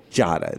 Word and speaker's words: Giada: Jada. It's one. It's like one syllable.